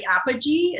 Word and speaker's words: Apogee: Apogee. Is that, am I saying that correctly? – Yes, ma'am.